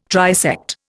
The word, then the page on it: trisect: Indian